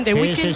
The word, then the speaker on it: The Weeknd: Miguel Ángel, lo he dicho bien. The Weeknd.